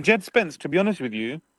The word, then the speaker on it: Jed Spence: I would say Jared Spence was man of the match, man. – Oh, 100%. Yeah, you're saying his name wrong, by the way. You're saying Gerard Spence. Jed Spence.